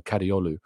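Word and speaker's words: Kadıoğlu: Thank you, Paul, for letting me know the G is pronounced silently. So Kadioglu, I suppose, is the way forwards.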